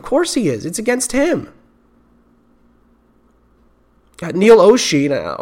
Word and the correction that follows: Olshey: or, Olshey, I apologize. I, I keep mispronouncing his name.